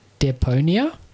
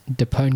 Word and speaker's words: Deponia: Goodbye, Deponia. Am I saying that right? – I think you're right. I would stick with that. – Deponia. – Just say it with confidence. It's fine. – Oh, yeah. Goodbye, Deponia.